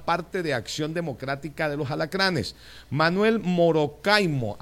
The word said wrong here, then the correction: Morocoima: ¿así? Manuel Morocoima, candidato del señor Mario Bonucci.